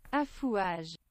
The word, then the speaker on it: affouage: Affouage.